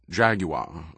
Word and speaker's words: Jaguar: He'd already bought a Jaguar, pronounced Jaguar over here, he'd have to remember, but both times he'd walked to it at the dealership, he'd gone to the left front door instead of the right.